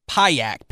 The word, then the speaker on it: Pajak: It's spelt Payak, Pajak.